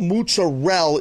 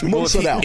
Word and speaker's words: mozzarella: No, but mozzarella is wrong, and that's a bad way to say it. It's mozzarella.